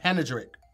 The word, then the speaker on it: panegyric: You're leaving out a syllable. – Oh, shit. Wait, is it panegyric? Panegyric. – No. – Mm-mm. – Panegyric.